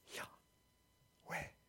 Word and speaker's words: Yahweh: But this is the way you say it. It's it's unpronounceable. You have to breathe it to say it. And it sounds like this. Yahweh. Did you hear that? Yahweh.